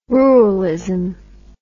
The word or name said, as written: ruralism